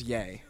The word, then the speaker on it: Ye: I think it's pronounced Ye. Because it's Kanye. Ye.